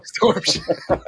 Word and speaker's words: Storm Shadow: This was, now that I'm thinking about it, this was a Shadow Storm. Did I say it right? – Storm Shadow.